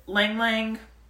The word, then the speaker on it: ylang-ylang: If you're someone who likes scents, you can heal with aromatherapy with scents like Lang Lang, that's spelled Y-L-A-N-G, Y-L-A-N-G, pine, rose, jasmine, or rosewood.